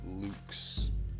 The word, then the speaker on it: LVX: So LVX, it means light in Latin. It's pronounced Lux.